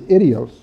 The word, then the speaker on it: idios: And the first one is idios. I-D-I-O-S. And idios is close to the correct pronunciation.